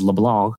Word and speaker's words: LeBlanc: And my friend TJ, his name is Timothy James LeBlanc, L-E-B-L-A-N-C. And he pronounces it that way, you know, with like the French. And he made me realize, and he explained to me that James Clement probably is James Clément, is how he probably says his name.